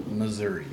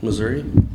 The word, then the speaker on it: Missouri: How do you say Missouri? – Missouri? – Oh.